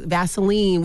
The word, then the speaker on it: Vaseline: And so when we think about like the lotions, the creams, like Vaseline, which rhymes with gasoline, like we're using stuff like this all the time because it's tried and true.